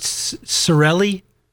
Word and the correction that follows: Saragelli: Saragelli, yeah. – Saragelli. It is the G. I thought the G belonged, but the other guys keep dropping it.